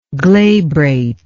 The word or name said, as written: glabrate